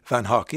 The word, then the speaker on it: Van Gogh: Stephen Fry and the QI Elves, that historical language experts have been insisting that Vincent van Gogh's name should not be pronounced as neither Van Gogh or Van Gogh, but actually Van Hock.